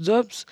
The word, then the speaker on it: DVBBS: Aujourd'hui, je vous présenterai la chanson Tsunami, écrite par DVBBS, qui se prononce Dobbs, qui est un duo canadien de musique électronique formé en 2012.